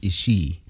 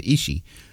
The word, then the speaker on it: Ishii: And so like you pronounce it Ishii. Everyone pronounces it Ishii. Even Japanese Americans say Ishii, but I remember Vin Scully would say Ishii.